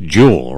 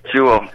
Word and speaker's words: Juul: I bet it is pronounced Juul, right? – It is Juul, pronounced Juul, but it's J-U-U-L.